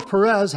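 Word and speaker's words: Peres: Viewer Lionel Perez had a couple of questions for us, and his last name is spelled P-E-R-E-S. I believe it's pronounced Perez. I hope that's correct, Lionel. Lionel Perez. Hope I got it right.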